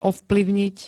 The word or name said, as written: ovplyvniť